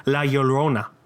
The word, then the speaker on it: La Llorona: La Llorona. – It's like quesadilla or tortilla. You could get, La Llorona.